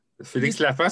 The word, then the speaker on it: Lafont: On est en compagnie de Félix Lapin, donc euh, de l'Union des, de l'Union des travailleurs et travailleuses accidentés en maladie. Est-ce que je prononce comme il faut? – Félix Lafont, c'est parfait.